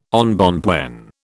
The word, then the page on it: embonpoint: British English